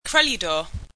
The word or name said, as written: kreludor